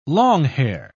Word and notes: longhair: British English

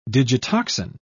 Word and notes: digitoxin: British English

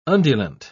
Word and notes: undulant: British English

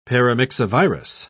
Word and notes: paramyxovirus: British English

